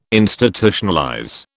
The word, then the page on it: Institutionalize: British English